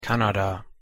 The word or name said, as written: kanada